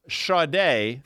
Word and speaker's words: Sade: But what I'll tell you is, if you read her name, you wouldn't pronounce it the way it's spelled. Now, Sade, spelled S-A-D-E, yes.